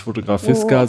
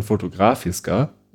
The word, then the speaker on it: Fotografiska: über die äh, Fotografiska gesprochen, habe ich jetzt gelernt, spricht man es aus. Nicht Fotografiska, oh. sondern Fotografiska. Oder zumindest wurde das so im Deutschlandfunk Kultur ausgesprochen.